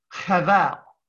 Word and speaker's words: Hevel: The Hebrew pronunciation, the word literally, Hevel.